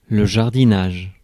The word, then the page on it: jardinage: [ʒaʁ.di.naʒ]; France